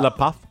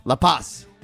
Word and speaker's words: La Paz: Is it La Paz? – La Paz. – You say the S? – Yeah, well, you say the Z. – La Paz. – La Paz. – You're doing the Castilian pronunciation.